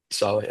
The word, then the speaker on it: Saleh: With the firing of Saleh, that's how you pronounce it, right? – So. – Saleh, yeah.